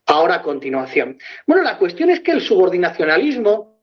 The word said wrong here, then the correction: subordinacionismo: Subordinacionismo, no subordinacionalismo, como dices tú. A ver si aprendes a pronunciar bien esa palabra. Subordinacionismo.